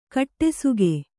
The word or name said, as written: ಕಡುಸೊಗ